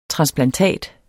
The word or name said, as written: transplantat